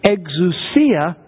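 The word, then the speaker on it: exousia (ἐξουσία): He uses the Greek word exousia.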